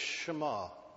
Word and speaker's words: Shema: I'm told that the correct way of pronouncing the Jewish word relating to verse 4 is Shema. It's their great declaration of faith, the great Jewish declaration of faith.